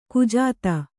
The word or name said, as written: ಕುಜಾತೆ